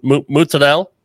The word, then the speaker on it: mozzarella: I've been to some places that uh, that use fresh mozzarella, sorry, mozzarella. – That's, what, uh, that's the correct pro- yeah. Italian pronunciation.